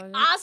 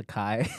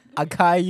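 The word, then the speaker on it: açaí: I was like, acai! I was like, that's how you say it! – Would have been better if he asked you, you're like, actually, it's acai. Akai you at prom?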